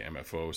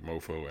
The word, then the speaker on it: MFO: I say MFO. Some people say it like MOFO, M-O-F-O, whatever.